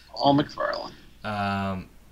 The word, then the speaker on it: Paul McFarland: Yep, Paul McFarland.